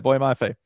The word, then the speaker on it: Boye Mafe: Boye Mafe at Minnesota, who is a monster.